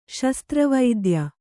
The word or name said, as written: ಶಸ್ತ್ರವೈದ್ಯ